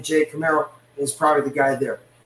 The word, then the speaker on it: Kumerow: I think um, Camaro, Camaro. That's how you technically say it.